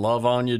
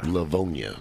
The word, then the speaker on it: Livonia: What's going on over in Livonia, Georgia? – Livonia. – Did I say that wrong? – You did.